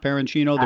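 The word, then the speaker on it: Perenchio: Is it Jerry Parenchino? Is that how it's pronouned, Parenchino, the promoter? – I believe so. – Okay. – That sounds right. That sounds phonetically correct.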